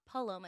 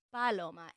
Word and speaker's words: Paloma: It's Paloma. And I'm like, yeah, it's really hard to just like jump into people and be like, oh, it's not Paloma, it's Paloma.